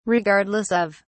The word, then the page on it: regardless of: British English